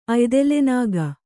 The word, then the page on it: ಅಯ್ದೆಲೆನಾಗ: Kannada